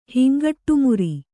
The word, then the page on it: ಹಿಂಗಟ್ಟುಮುರಿ: kannada